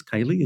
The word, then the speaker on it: Kali: Kaylee is the correct pronunciation.